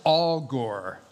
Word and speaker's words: Agur: A-G-U-R, but it's pronounced Al Gore, like that.